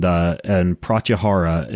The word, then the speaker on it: pratyahara: pratyahara, and go ahead and slap me on the wrist if I'm totally butchering the pronunciation.